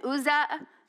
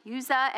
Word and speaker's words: Uzzah: And brought it out of the house of Abinadab, which was on the hill and Uzzah, or Uzzah, I got that name wrong, Uzzah and Ahio, the sons of Abinadab, drove the new cart.